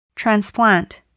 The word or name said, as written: transplant